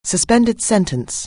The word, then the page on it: suspended sentence: anglais